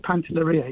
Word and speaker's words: Pantelleria: I said Pantelleria.